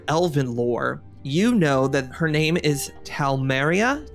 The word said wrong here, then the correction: Talamira: T-A-L-A-M-I-R-A. – Talamira. – What? – Talamira. – Talamira, thank you.